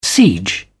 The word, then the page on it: siege: British English